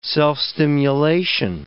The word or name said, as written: self-stimulation